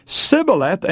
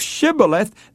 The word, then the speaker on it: Shibboleth: Well, what they did was make the person pronounce the word Shibboleth. And if that person said Sibboleth instead of Shibboleth, they knew it was the enemy and they slew them as traitors.